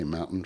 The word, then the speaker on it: mountain: Philadelphians don't pronounce the T. See, they say mountain, fountain, counting.